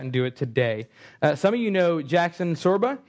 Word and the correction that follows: Sherba: He's here tonight. His mom, say that again. Sherba. I say it wrong all the time.